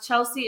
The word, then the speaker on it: Chelsey: Chelsey is spelled Chelsey, not with an A.